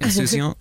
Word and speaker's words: insouciant: I can't pronounce it. Insouciant? – No, that's right.